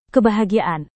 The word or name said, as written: kebahagiaan